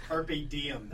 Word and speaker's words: carpe diem: I teach the real one in my class. It's carpe diem. That's how you say it.